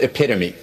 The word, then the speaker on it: epitome: This word for a perfect example doesn't rhyme with home. What is epitome?